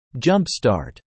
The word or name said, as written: jumpstart